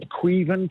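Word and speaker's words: Caoimhín: And I think it's Quaven Kelleher is how I would say it.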